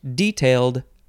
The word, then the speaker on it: detail: The synonym is specify, as in detailed their grievances. So there I pronounced it with the emphasis on the first syllable, which I think made sense.